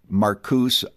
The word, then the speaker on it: Marcuse: Marcuse oftentimes is the way in which his name is simply pronounced.